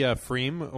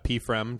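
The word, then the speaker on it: pFriem: Yeah, it's the Freem or PFREM, depending on PFREM. If you know how to pronounce it or not.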